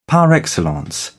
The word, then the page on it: par excellence: British English